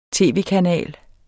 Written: tv-kanal

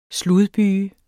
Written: sludbyge

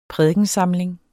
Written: prædikensamling

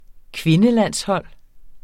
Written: kvindelandshold

